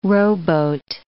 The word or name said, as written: rowboat